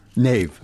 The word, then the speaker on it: Niamh: We've got Niamh? Is that pronounced your... Is that your... – Niamh. – Niamh, okay, sorry.